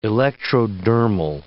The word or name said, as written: electrodermal